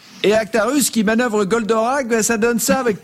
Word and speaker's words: Goldorak: C'est du dessin animé Goldorak. Hein. Charline, vous allez rire, vous allez voir. Mais pendant longtemps, j'ai cru qu'on disait Golderay. C'est amusant, non? – Alors, en trois syllabes, O-D-K.